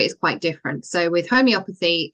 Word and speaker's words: homeopathy: So I must admit, before I heard you explaining this, I assumed it was, I can never say this word properly, homeopathy.